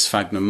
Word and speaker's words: Sphagnum: Is it Sphagnum moss? – Sphagnum, that's right.